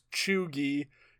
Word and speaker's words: cheugy: C H E U G Y, pronounced Chugi.